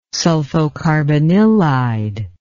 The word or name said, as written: sulfocarbanilide